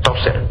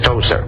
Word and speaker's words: Tozer: Aquí le pronuncian Tozer, como decir toser, pero es Tozer.